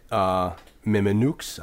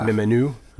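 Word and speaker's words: Mimieux: I can never do her name right because I suck at pronouncing French. – Yeah. – Mimenu. – Yeah, it's one of those ones with the E. Yeah, E-U-X at the end.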